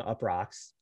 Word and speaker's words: Uproxx: Uproxx.